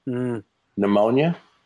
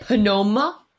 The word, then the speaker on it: pneumonia: There's the silent P to me is the stupidest of silent letters. Pneumonia? – No. Do you hear a P? Is it Panoma? No. I'm done.